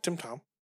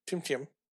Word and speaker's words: TemTem: I'm going to say it wrong. Like TemTom. TemTem.